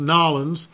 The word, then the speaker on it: New Orleans: Actually, it's pronounced Nolens, as I had always been intrigued by the beauty and history of the city.